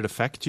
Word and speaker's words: affect: That word is spelled E-F-F-E-C-T. – The confusion happens because we often pronounce the two words the same way. When we are speaking quickly, the first vowel of the noun, effect, is reduced to the quick sound, affect, which is the same sound as the verb.